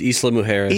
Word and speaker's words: Isla Mujeres: It would take place on the, is that Isla Mujeres? I don't know how to pronounce it. You know how to pronounce it? – Yeah, it's off the coast of Mexico. It's Isla Mujeres.